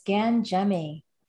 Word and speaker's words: Gangemi: It's very close, Tom. It's Gangemi.